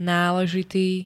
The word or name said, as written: náležitý